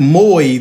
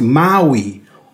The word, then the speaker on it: Maui: And while I'm doing this, an operator pointed out to me, that's not Maui, that's pronounced Maui.